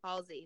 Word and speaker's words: Halsey: Halsey, thank you.